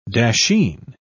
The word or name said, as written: dasheen